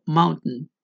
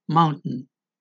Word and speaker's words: mountain: muchos hablantes pronuncian la T cerrando las cuerdas vocales y no suena la T, por ejemplo, mountain, mountain, o la palabra algodón, cotton, es cotton, también se encuentra en la palabra button, botón.